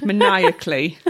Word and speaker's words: maniacally: maniacally. Is that how you say that word? – That is how you say that word, but I don't know what it means.